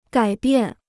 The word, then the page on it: 改变: gǎi biàn